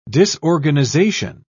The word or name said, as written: disorganization